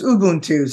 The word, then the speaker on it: Ubuntu: Yeah, it's Ubuntu. So, you know, I'm going to be quick, but I have to share with you how I stumbled across this term. Ubuntu means I am because we are, or I am because you are.